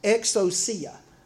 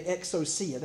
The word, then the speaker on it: exousia: Okay, so uh, they are challenging Jesus' authority, or what is actually pronounced exousia. Okay, exousia, that's a Greek word.